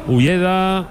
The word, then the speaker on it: Ueda: Ulleda, o Ueda se escribe, le llaman Ulleda, Ueda.